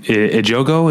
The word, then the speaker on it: Ejogo: It's got Joel Edgerton and Carmen Ejogo. Is that how you say her name? – Oh, okay, yeah.